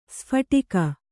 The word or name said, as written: ಸ್ಫೋಟಕ